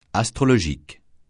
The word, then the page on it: astrologique: francais